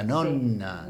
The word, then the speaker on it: nonna: Nosotros tal vez lo decíamos con una N sola, pero en italiano vero, puro vero, es doppia N, en el final. Sería nonna, sí. Nonno, porque las doppias se pronuncian.